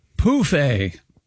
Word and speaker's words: pouffe: Anyway, Europeans of a certain age use the French word pronounced pouffe for an ottoman, phonetically spelled P-O-O-F hyphen A.